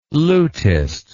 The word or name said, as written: lutist